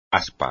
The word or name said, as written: aspa